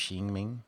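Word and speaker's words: Qi Ming: It's spelled King Ming, like with a Q. I think it's pronounced Xi Ming.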